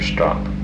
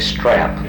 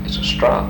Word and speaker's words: strop: Well, some say strap, yeah. – Razor strap. But I'm not so sure, but what actually it's a strop. And really, not a strap.